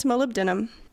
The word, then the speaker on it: molybdenum: I think it's molybdenum. – Molybdenum. – Yeah. Molybdenum. Yeah. It looks like Molly B denim, but that's fine. Molybdenum.